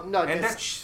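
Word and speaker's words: Andechs: What's that? Andex? – No, Andesch. Andesch.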